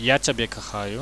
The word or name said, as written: ja ciabie kachaju